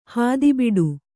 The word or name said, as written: ಹಾದಿಬಿಡು